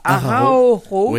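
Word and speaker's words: Araújo: qui sont Monsieur de Araujo. Je prononce pas. Araujo. – Oui.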